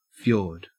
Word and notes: fjord: Australian English

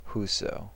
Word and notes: whoso: American English

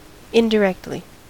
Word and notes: indirectly: American English

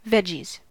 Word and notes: veggies: American English

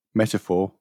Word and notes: metaphor: British English

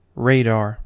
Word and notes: radar: American English

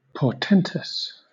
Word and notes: portentous: British English

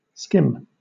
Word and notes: skim: British English

